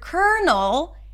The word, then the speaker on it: colonel: There is no L because in the word colonel, there is an R sound. And yes, I know, it's weird, but that's the only word in English that is spelled with an L but pronounced with an R. So to say this word, you want to start with a k sound and then bring the tongue up for the R. Cur, like the word sucker.